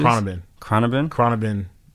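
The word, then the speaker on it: Khruangbin: Cronabin. That's how you say it. They got a weird name, but Cronabin.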